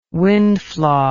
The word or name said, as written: windflaw